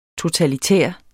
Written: totalitær

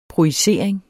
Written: projicering

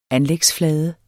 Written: anlægsflade

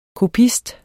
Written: kopist

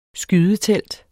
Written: skydetelt

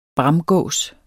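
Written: BRAMGÅSEN

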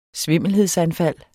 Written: svimmelhedsanfald

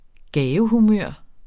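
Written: gavehumør

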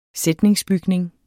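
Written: SÆTNINGSBYGNINGEN

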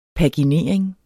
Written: PAGINERING

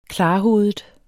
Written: klarhovedet

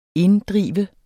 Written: INDDREV

